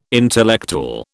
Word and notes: intellectual: British English

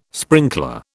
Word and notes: sprinkler: British English

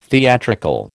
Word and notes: theatrical: American English

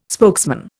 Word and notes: spokesman: Indian

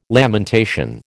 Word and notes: lamentation: American English

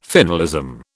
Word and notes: finalism: British English

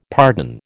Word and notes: pardon: American English

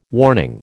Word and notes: warning: American English

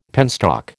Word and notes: penstock: American English

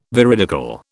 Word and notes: veridical: British English

